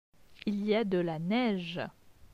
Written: Il y a de la neige

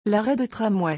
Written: l'arrêt de tramway

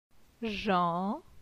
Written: Jan

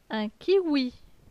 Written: Un kiwi

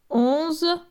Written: 11

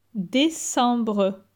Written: Decembre

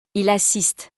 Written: il assiste

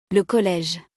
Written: le College

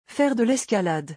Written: Faire de lescalade

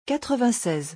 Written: 96

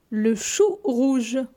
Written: Le chou rouge

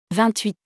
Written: 28

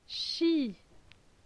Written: Chi

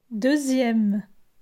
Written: Deuxieme

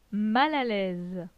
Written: Mal a l'aise

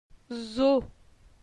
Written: Zo

